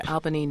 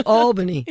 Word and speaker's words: Albany: Now retired, lives near Albany, New York. Did I just mispronounce Albany? – Yes, it's Albany.